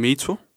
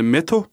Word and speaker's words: Meto: Jeg hedder Metin, og går faktisk under kunstnernavnet Meto. – Prøv lige at stave det en gang. – M-E-T-O. Normalt vil man udtale Meto, ja.